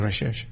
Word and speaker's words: Recherche: It was trawled off the, you can tell me how to pronounce this, Recherche? – Yeah, Recherche. – Archipelago. – Yep. So down south. – Yep. – Yep, nice. – That's named after the Frenchman. – Recherche. – No, Recherche.